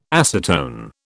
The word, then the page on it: acetone: British English